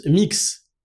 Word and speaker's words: Mx: MX se prononce mix et